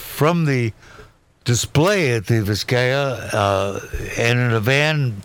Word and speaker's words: Vizcaya: So correct my pronunciation if, I, right. I'm, in, if I'm incorrect. The Vizcaya Museum. Vizcaya, yeah.